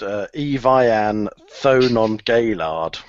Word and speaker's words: Evian Thonon Gaillard: Firstly, I think it's actually pronounced Evian Thonon Gaillard.